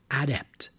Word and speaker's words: adept: The word can also be used as a noun, but for the noun, the stress is on the first syllable. The person is now an adept, adept, someone skilled in this particular profession.